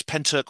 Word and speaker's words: Pentyrch: And it's actually pronounced, it's Pentyrch or Pentyrch, is how you pronounce it.